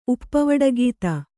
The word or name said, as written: ಉಪ್ಪವಡಗೀತ